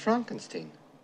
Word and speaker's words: Frankenstein: No, it's pronounced Frankenstein.